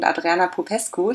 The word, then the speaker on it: Adriana Popescu: Ich habe heute zwei ganz besondere Gäste bei mir, nämlich die Autorinnen Anne Freitag und Adriana Popescu.